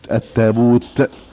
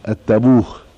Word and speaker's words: التابوت: فقال اكتبوه التابوت بالته الايه المفتوحة علشان ما يجيش حد يقراها ايه التابوه.